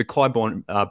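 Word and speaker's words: Clybourn: There's one on the Clybourne brew house is at 800 North, I believe, as well. – Good pronunciation of Clybourne this time.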